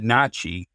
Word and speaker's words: NACHI: Yeah, there's an ASHI, A-S-H-I, that stands for the American Society of Home Inspectors. And I pronounce it NACHI, you know, the National Association of Certified Home Inspectors.